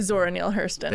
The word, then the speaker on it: Zora Neale Hurston: You can say her name because I'm not Zora Neale Hurston.